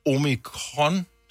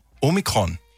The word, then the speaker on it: Omikron: Ja, så man starter med O som Ole. Omikron, tror jeg, det siger. – Omikron. – Nej, ikke kron nemlig. – Nå. – Omikron.